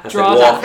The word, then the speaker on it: Drogheda: You're from Drogheda. – I have like technical Cork Drogheda. – Drogheda. – Drogheda. – How do you spell it? – D-R-A-W-D-A. – Drogheda. – Drogheda. Literally Drogheda. Drogheda.